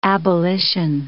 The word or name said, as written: abolition